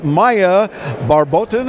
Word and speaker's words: Mhaia Barbotin: who's Maya Barbotin.